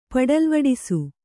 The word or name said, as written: ಪಡಲ್ವಡಿಸು